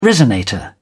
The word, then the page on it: resonator: British English